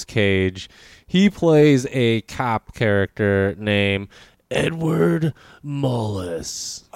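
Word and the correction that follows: Malus: I'm pretty sure it's Malice. – And I'm pretty sure it's supposed to rhyme with phallus.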